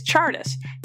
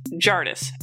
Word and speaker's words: Tjardus: Also, his first name is Chardus, T J A R D U S. It's not T. Jardis, as listed on the VHS release of the movie.